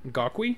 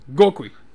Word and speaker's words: Gokwe: Where there was, apparently there's work being done on these two reservoirs near G-O-K-W-E. Gokwe? – Say it like you mean it. – Gokwe. – There we go.